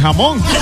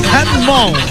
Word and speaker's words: Hammond: Ya, el apellido fue el que lo dije mal. – Sí. – Ya, entiendo. Bueno, ahí está Albert Hammond con el tema Échame a mí la culpa. Por poco le ponen Albert Jamón. Hammond, ok, Albert Hammond.